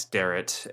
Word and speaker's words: Derrat: It's Max Derrat. And my last name is spelled D-E-R-R-A-T.